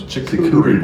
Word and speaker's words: charcuterie: So you want some, you know, now if you go somewhere, they got, I call them, what are they, chicory plates or something? – Charcuterie boards? – Charcuterie boards.